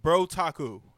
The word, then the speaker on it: BrOtaku: It's literally Otaku with bro in front. – BrOtaku. – There you go. – The BrOtaku. – Otaku means nerd in Japanese. – Okay. The BrOtaku Alliance, I think it's the BrOtaku.